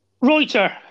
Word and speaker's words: Reuter: Reuter.